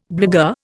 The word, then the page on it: blagueur: Indian